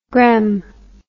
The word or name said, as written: grem